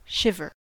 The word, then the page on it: shiver: American English